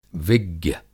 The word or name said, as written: विज्ञ